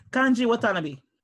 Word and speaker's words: Kanji Watanabe: So his name, Ikiru is To Live, and his name is Kanji Watanabe, but pronounced well. – Kanji Watanabe. – Thank you. See, pronounced like that. Not the way I did it.